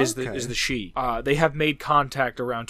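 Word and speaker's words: Shi: I think it's pronounced She, the S-H-I, a faction from San Francisco.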